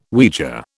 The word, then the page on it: Ouija: British English